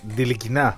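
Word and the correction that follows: Ntilikina: Νι. Το τάφ δεν προφέρεται. Στα γαλλικά. – Θα βαρεύει ο Γιάννης Ροζής. – Θα σου βάλω πώς το λέει ο ίδιος. – Ο ίδιος λέει τι. – Φρανκ Νιλικίνα.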